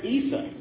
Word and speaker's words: Isa: And I thought I heard that some use the term Isa. Is that correct? Muslims use the term name Isa for Jesus. But they're talking about the same person. All right. Yeshua, Isa.